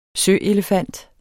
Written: søelefant

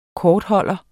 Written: kortholder

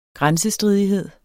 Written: grænsestridighed

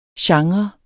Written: genre

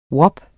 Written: WAP

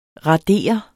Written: radere

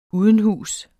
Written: udenhus

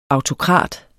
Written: autokrat

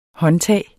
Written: håndtag

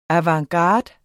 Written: avantgarde

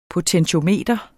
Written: potentiometer

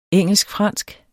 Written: engelsk

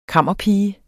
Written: KAMMERPIGE

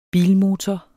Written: bilmotor

